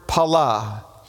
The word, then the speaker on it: pala: And it's the Hebrew word pala, P-A-L-A, pala, pala, accent on the second syllable.